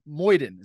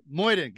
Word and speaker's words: Mojden: And I'm here today with a new friend of mine, someone I've connected with pretty recently, actually, Rafael Mojden. Is that how you say it? Did I say it right? Mojden, got Nailed it.